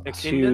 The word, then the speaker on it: echidna: Is that how you say it? Echidna or echidna? – Echidna. So echidna. – Echidna. – Echidna. – Echidna. – Echidna. – I don't know. – Echidna. – That's exactly how it's said.